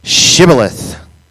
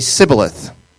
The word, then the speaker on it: shibboleth: If he was from Ephraim, though, he would say sibboleth. Because people from Ephraim cannot pronounce the word correctly.